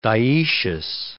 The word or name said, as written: dioecious